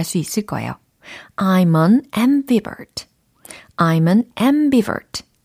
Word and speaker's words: ambivert: A, M, B, I, V, E, R, T.